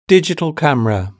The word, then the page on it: digital camera: British English